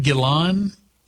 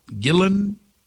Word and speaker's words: Gillan: But it was a last minute addition, and the lead singer, I don't know how do you pronounce that fellow's name? I think it's Gillan or Gilla in it. No, not Robert Plant. Is it Ian Gillan? Gillan? Okay.